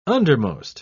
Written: undermost

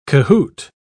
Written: cahoot

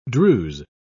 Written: druse

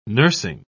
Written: nursing

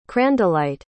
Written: crandallite

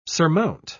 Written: surmount

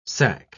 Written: sac

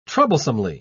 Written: troublesomely

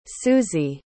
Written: sussie

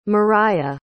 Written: mariah

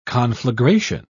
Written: conflagration

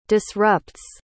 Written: disrupts

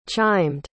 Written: chimed

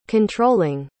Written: controlling

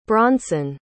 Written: bronson

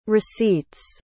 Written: receipts

